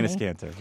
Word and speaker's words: Enes Kanter: Enes Kanter, yeah.